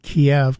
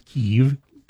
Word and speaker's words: Kyiv: Seeing headlines across a couple of the news channels saying Kyiv is expected, or as Chris and I like to call it, Kiev, because that's the way we've pronounced it our entire lives. – Yeah, until last year when they told us it was Kyiv.